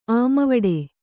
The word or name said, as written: ಆಮವಡೆ